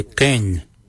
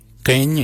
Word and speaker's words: Geny: For 1, we say Keng. Keng means 1. Keng is spelled G-E-N-Y. Keng. Number 2, we say Kujo. Kujo for number 2. Kujo is spelled G-O-O-D-J-A-L.